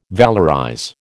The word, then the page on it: valorize: American English